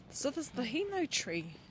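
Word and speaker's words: hinau: Heno. How do you spell that? – H-I-N. – Yeah, so heno.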